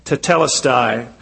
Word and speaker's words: tetelestai: He used that Greek word, telestia, I'm not sure if I'm pronouncing that right, tetelestai, which means it is finished.